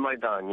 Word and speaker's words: Maidan: And these protests are centered around Kiev's Independence Square, known as, the Maidan. Am I saying that right? – The Maidan, yes.